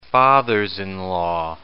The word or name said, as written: fathers-in-law